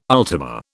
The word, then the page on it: ultima: British English